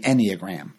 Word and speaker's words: Enneagram: And that's an odd word, the Enneagram. First of all, it's spelled E-N-N-E-A-G-R-A-M, if you want to look it up. Ennea, I believe, is 9 in Latin.